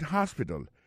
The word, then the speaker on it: hospital: She likes to visit hospital. Elle aime aller visiter l'hôpital. L'hôpital, c'est comme ça qu'on dit ça en anglais. Hospital. Alors, accent tombe sur le premier syllabe, il y a trois syllabes, puis she likes to visit hospital. Ensuite, elle a écrit H-O-S-P-I-T-A-L, hospital, l'hôpital.